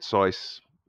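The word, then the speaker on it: Saïss: So for example, I'm going to bring out the fact that Their player that got red carded, Sice, that's how you pronounce his name, the two yellow cards, both of them were Ward and Zaha working together, breaking, and him having to make tactical fouls.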